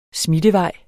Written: smittevej

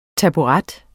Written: taburet

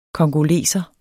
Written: congoleser

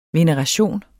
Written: VENERATIONERS

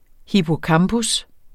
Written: hippocampus

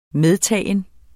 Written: medtaget

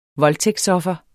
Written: voldtægtsoffer

